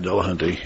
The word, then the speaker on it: Dillahunty: Matt Dillahunty, yep. And his name is spelled just like it sounds, with a Y at the end, though.